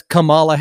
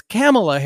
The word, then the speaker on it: Kamala: You can go back and look at clip after clip after clip where she's introduced to on shows like The View as Kamala Harris. It's Kamala Harris is how she pronounces it.